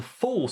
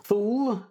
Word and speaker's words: Thule: Thule, or in German, as we'll see, it's Thule, was a land mass of some kind referred to by the ancient Greeks and the ancient Romans. According to the pronunciation guide I read in ancient Greek, it was pronounced Thule.